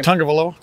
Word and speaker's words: Tagovailoa: They get pressure, and if you have a quarterback like Tagovailoa, however you say his name. – Tagovailoa.